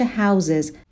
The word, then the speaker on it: houses: تلفظ این کلمه, کلمه house, وقتی که اس جمع میگیره, معمولا میشه houses, ولی houses هم بگین اشکالی نداره ولی رایج‌تر اینه که گفته میشه houses.